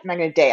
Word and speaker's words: Magna Dea: I keep saying it, thinking I'm pronouncing it wrong. Magna nope. – Dea? Magna Dea. I got it right.